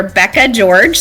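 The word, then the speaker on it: Bekah George: It is under Becca George. So my name is spelled a little weird. So it's B-E-K-A-H George, G-E-O-R-G-E.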